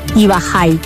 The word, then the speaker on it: Ubajay: Su nombre, pronunciado desde el guaraní, Ubajay.